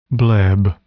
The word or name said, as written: bleb